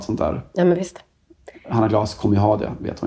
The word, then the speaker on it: Hanna Glas: Jag skojar när jag säger Anna Glas, såklart. Hanna Glas. Eh, ja, du la eh, på man, det franska uttalet. Det var helt rätt.